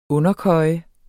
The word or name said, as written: underkøje